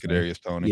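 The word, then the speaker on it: Kadarius Toney: Kadarius Tony.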